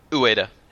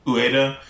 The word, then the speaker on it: Ueda: Ueda. – Ueda.